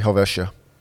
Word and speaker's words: Helvetia: It's actually Helvetia. – Helvetia. – Helvetia. Helvetia. The H is silent.